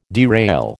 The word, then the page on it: derail: American English